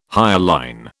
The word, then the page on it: hyaline: British English